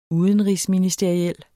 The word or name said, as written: udenrigsministeriel